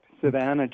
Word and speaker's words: Savannah: Am I pronouncing that right, Savannah? – That's correct, Savannah, Georgia.